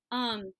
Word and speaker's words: hygge: Yeah, it looks like Hygge, but it's Hygge.